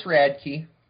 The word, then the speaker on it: Radke: No, it's Radke. It's close. It's one of them names that aren't spelled like it's pronounced.